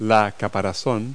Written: carapace

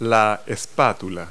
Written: spatula

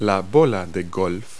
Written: golf ball

